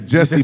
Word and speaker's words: Jussie: His name is Jesse, but it's spelled J-U-S-S-I-E.